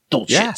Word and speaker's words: Dulcich: Albert O wasn't even a better prospect than the guy that you want to talk about right now, which is Greg Dulcich. Did I say that right? Dulcich. – Yeah.